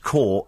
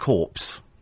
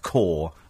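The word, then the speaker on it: corps: That the word core is pronounced core, not corpse. As in RAF core. Are we saying... We're not saying corp, surely not. I do hope not. But it's core. C-O... It's as in apple core.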